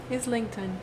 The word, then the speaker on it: Islington: Islington.